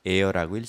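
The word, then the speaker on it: heir: 또 피붙이 상속자, 나에게 달라붙은 상속자라는 뜻도 here라고 그랬는데 그때는 H-E-I-R을 써요. 그리고 air라고 읽습니다.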